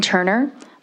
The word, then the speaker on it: Turner: My name is Robin Turner. My last name is spelled T-U-R-N-E-R, and I'm here on behalf of the City of Whitefish, and we rise in support of this bill.